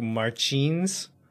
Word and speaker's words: Martins: And I think the Portuguese pronunciation is more like Felipe, and then the last name is like Martins.